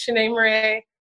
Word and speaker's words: Shanee Moret: So I said, you know, it's Shanee Moret.